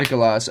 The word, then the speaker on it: Mikolas: Miles Mikolas. Did I Mikolas, say that right? Yeah, yeah. Mikolas.